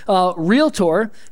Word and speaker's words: realtor: Realtor. Now, a lot of you don't think that's difficult to pronounce, but you're putting three syllables in there, and it's realtor.